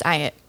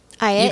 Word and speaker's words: Ayotte: It's Ayotte. Ayotte.